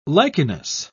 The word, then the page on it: lichenous: British English